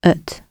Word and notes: at: British English

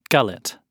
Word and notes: gullet: British English

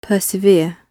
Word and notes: persevere: British English

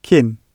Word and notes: kin: British English

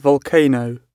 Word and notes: volcano: British English

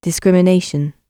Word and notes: discrimination: British English

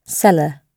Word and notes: cellar: British English

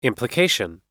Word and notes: implication: American English

